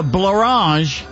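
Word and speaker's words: Blorenge: A rare example of a word that rhymes with orange, there is one. It's called the blorange, is what?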